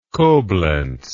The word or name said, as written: Koblenz